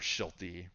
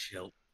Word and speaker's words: Shildt: under Shildt or Schilty, whatever the hell his last name is. I hear I hear people pronounce it both ways. It's Shildt. It's just Shildt.